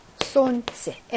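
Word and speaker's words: 손치 (солнце): 손치, 엘 발음 안 나고요.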